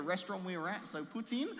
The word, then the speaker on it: poutine: This next one, I hope I'm pronouncing it right, is poutine.